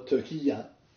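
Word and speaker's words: Türkiye: This is a prime indication that Turkey, or Turkiye, if you wish, the Turkish pronunciation of it, will move closer economically to the state of Israel and the West than many have previously thought.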